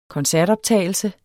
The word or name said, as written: koncertoptagelse